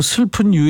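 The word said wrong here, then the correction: Surfin': 슬픈 아니고 썰핀. 아 진짜 왜 이래요 오늘. 슬픈 USA 그것도 말되네. 아이 참 슬픈 아닙니다. 썰핀 USA예요.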